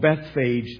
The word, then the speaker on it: Bethphage: They came to what's called Bethphage there, pronounced different ways, but that's a common one.